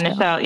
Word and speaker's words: Nichelle: Am I saying her name right? – Yeah, Nichelle. Yeah, that's right.